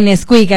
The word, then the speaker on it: Nesquik: El 26 de julio estará Nesquik, sí lo dije bien. – Sí, Nesquik, así, Nesquik. Así se dice, como el Chocomilk.